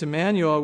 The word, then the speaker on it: Emmanuel: That's how we know that it's true, which means Emmanuel, which means God with us. This word's just straight from Hebrew. You pronounce it very much like that in Hebrew.